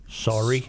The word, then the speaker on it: saury: Surrey fishing, S-A-U-R-Y. Pronounce that for me. – Sorry.